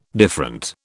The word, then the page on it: different: British English